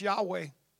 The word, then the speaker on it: Yahweh: It was actually pronounced Yahweh.